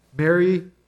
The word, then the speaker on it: Mary: Now, I think for people like Ted, who are from New Jersey, Mary, M-A-R-R-Y, and M-A-R-Y, Mary, the mother of Jesus, are pronounced differently. But for this California kid, pronounce it the same. So God speaks to Joseph, to Mary, M-A-R-R-Y, Mary, M-A-R-Y.